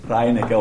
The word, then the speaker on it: Reinecke: Ich rufe ihn Reinecke, ich weiß nicht, wie sagt man das richtig, ob Reinecke oder? – Reinecke, okay, so ich habe immer richtig gesagt.